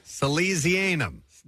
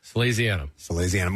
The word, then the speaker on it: Salesianum: Let's give you a lesson on how to pronounce his high school. – Oh, yes. Salesianum. – No. – Salesianum.